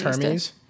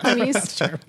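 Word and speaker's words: Hermès: It's pronounced Hermes. – Hermes? – It's not Hermes. – Make it sound like an STD. – Hermes. – It's Hermes.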